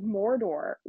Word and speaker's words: Mordo: Oh, speaking of, I was like, Mordor. Why are they talking about Mordor? Like fucking Lord of the Rings? Mordor? And I Googled it and it's Mordo.